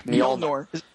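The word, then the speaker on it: Mjölnir: Mjolnir. – Yeah, sure. Whatever. – Mjolnir, it's easy.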